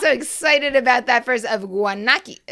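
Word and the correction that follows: Guánica: Uh, wait, Guanakia. Can I say? Can I pronounce Guanica? that? – Guanica. Guanica. Guanica. Guanica. That's it, Guanica.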